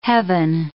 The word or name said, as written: heaven